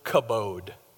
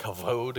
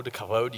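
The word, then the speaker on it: kavod (כבוד): is the word kabod. Kabod. Now in Hebrew, you wouldn't say it like a B. You got to get that going. So it's kind of a V sound. It's like kavod, kavod.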